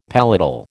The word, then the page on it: palatal: American English